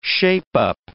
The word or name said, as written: shape-up